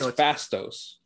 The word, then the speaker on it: Faustos: Next up, It sounds like they're saying Faustos, but there's no U in the pronunciation. – I think it's Fastos. – It's Fastos. Spelled Faustos, but it sounds...